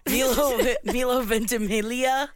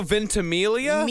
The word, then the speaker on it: Ventimiglia: Milo Ventimiglia. – Milo Ventimiglia? – Milo. – Milo. – It looks like Ventimiglia, but apparently the G is silent, which so it just makes it really difficult to say.